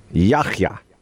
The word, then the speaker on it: Yahya: Yahya. – Yahya. – Yeah, it's not a hard K sound. Yahya. It's like Yahya.